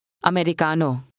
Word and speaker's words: Americano: Americano. Cano. Ri. Ricano. Americano. Americano. – If you have trouble with the Tagalog R, try pronouncing it like the T in the English word water.